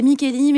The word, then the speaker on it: Micheli: Ouais, M-I-C-H-E-L-I. C'est oui, ça voilà, c'est Micheli, mais ça s'écrit Micheli. M-I-C-H-E-L-I.